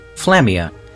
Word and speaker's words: Flammia: Flammia. – Flammia. You can press Control M, as in mother, to check how the Manamon's name is spelt. – F-L-A-M-M-I-A.